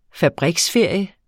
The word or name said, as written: fabriksferie